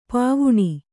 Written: ಪಾವುಣಿ

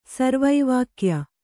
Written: ಸರ್ವೈವಾಕ್ಯ

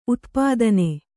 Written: ಉತ್ಪಾದನೆ

